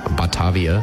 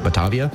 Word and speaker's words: Batavia: By analyzing the stone blocks carried by the Dutch East India Company ship Batavia, I think is how you say that, Batavia, which sank off Australia's coast in 1629, scientists made a surprising discovery.